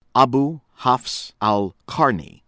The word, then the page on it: Abu Hafs Al-Qarni: American English